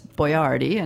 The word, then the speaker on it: Boiardi: But the name is Boyardee, and it's spelled differently: Boiardi.